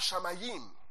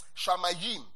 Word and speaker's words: shamayim: The word here, heaven, is the Hebrew word shamayim. Sounds like tongues, huh? Shamayim. Heaven is Shamayim.